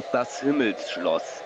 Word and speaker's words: Himmelsschloss: How do you say what he calls his castle in German? – Das Himmelsschloss. – There we go. I was trying to say it, but I was murdering how you say it. I said Himmelsschloss. Sounds so bad.